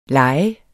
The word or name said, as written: lejer